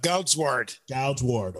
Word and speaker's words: Goudzwaard: Goudzwaard.